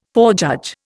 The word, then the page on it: forejudge: Indian